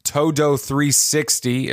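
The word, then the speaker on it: Todo 360: Yeah, we'll start the show after these ads specifically chosen for you by Cad Bane's droid Todo360. Is that how it's pronounced? – Yes, let's go.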